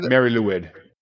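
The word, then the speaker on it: Mari Lwyd: And again, the prime mispronouncing that, Mary Lewid, I believe. – Mary Lewid. Mary Lewid.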